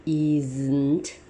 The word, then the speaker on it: isn't: Eyes，这个要念成 isn't。